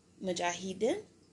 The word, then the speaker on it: Mujahideen: So in 1980, President Carter initiated a program to arm the, now forgive me if I say this wrong, the Mujahideen? – Yeah.